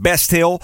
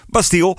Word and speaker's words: Bastille: Heel veel mensen die zeggen Best Hill. Eh, Bastille hoor ik ook nog regelmatig voorbij komen. Dus ik vroeg hem, joh, hoe spreek je jullie naam nou precies uit? Het antwoord was heel simpel, Bastille.